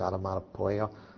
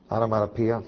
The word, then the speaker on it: onomatopoeia: I think that was that term that was used, onomatopoeia. I'm saying it wrong. Onomatopoeia. Okay, pia. All right.